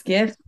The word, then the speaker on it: GIF: That is a hard G. It's GIF. – It's GIF? – GIF.